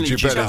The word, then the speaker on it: Jubel: Jubel. I'm going to pronounce it like that. – Jubel. Jubel? – No, Jubel. – I think Jubel is the one.